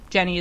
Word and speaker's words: Jenne: Jenny is spelled J-E-N-N-E because that's the way that my name is spelled.